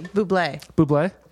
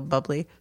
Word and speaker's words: Bublé: We're putting it's actually Bublé. Yes. – Oh, boy. Michael's going to have things to say about that. – I call him Michael Bubbly. So this is. – So now you're pronouncing Bubbly as Boobly? – No, I say Michael Bubbly. So this must be Bublé.